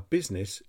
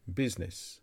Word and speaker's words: business: This word has several issues. The letter U is pronounced with an I sound, and the letter I is silent. Business.